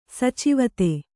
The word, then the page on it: ಸಚಿವತೆ: sacivate